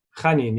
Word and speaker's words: Janín: Is it really Mikel Janín? Janín, yes. – Janín. Okay, cool.